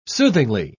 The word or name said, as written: soothingly